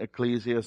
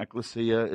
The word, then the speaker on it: ecclesia: Now, in the New Testament, when the word, some pronounce it ecclesia, some pronounce it ekklesia, it's the Greek word, and it means the called out ones.